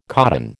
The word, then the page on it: codon: American English